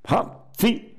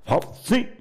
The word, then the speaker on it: Fawzi: Los dos tantos lo había convertido Aman, ¿cómo se pronunciará, no? F-A-W-Z-I. Fauci. Fauci. Fauci.